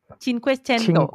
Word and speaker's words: Cinquecento: And then also the, I don't know if I pronounce this correctly, Cinquecento? Is that correct? – Cinquecento. – Oh, cin. – Yep. – Cinquecento. – Cinquecento, yes.